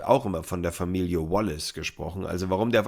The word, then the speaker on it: Wallace: Außer der Spieler sagt übrigens mal, dass ich seinen Namen falsch ausspreche, so bleibe ich bei Wallace, weil ich habe mal den Dolmetscher von ihm gehört in einem Interview und da hat er ihn mit Wallace angesprochen